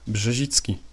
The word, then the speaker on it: Brzezicki: And it's Mark in Polish is Marek, and Brzezicki is Brzezicki. So Mark Brzezicki is Marek Brzezicki.